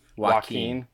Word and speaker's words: Joaquin: Joaquin.